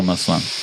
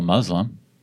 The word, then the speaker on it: Muslim: Doesn't mean you're not a real Muslim. – Right. – Muslim. How do you say it? – Muslim. – Muslim? – Yeah. – Is Muslim incorrect? – Yeah, it's like a muzzle. It sounds bad.